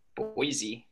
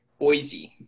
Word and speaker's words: Boise: Boise. – Sorry, can you say that one more time? – Boise.